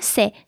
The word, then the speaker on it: ceux: E as in de, but without the D, so it's spelled E-U-X, e. And the demonstrative pronoun associated to it would be ce, just C-E-U-X.